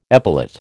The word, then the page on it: epilate: American English